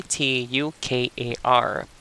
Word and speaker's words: tukar: t u k a r